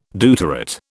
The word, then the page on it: deuterate: British English